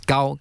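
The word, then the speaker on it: gao (糕): And also today we have prepared some other special food, such as Chinese rice, glutinous rice cake, and cake in Chinese, according to the pronunciation in Chinese, is gao.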